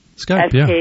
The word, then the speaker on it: Skype: And there's one called, I don't know if I'm going to pronounce it correctly, Skype. – Skype.